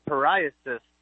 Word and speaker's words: psoriasis: Yeah, so I was dealing with like some scalp psoriasis.